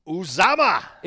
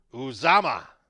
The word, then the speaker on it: Uzomah: And last thing, if you're desperate for a tight end, CJ Uzomah is 26% owned. – Oh, you got to say it right. – I didn't. – Uzomah. – It's Uzomah? – Uzomah.